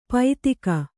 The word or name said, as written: ಪೈತ್ತಿಕ